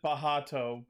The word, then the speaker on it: Bacht: They pronounce it Bahato, but it's spelled B-A-C-H-T, so Bacht.